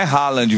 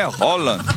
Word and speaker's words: Haaland: Mbappé, Haaland, que não é Haaland, viu? Ele ensinou. Foi ele que ensinou. Ele falou: a pronúncia do meu nome é Haaland.